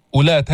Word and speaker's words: أولات: وإن كن أولات، أولات هذه الواو لا تلفظ، انظر عليها علامة، هذه الدائرة علامة على زيادة الحرف وعدم النطق به، فما بتقول أولاتي، ماذا تقول؟